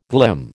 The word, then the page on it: glim: American English